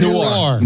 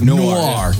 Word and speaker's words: Noir: I can't ever say Noir. – Noir.